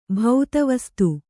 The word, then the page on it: ಭೌತವಸ್ತು: Kannada